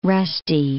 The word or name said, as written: rusty